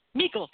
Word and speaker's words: Meikle: Meikle, you already know that you love William Meikle, then you'll still enjoy hearing this.